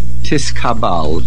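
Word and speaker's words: Tiskabal (תתקבל): Therefore, the word Tiskabal is Lashon Aramis, and the correct pronunciation is Tiskabal.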